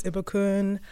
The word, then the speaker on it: Ibukun: Hi everyone, uh, my name is Ibukun.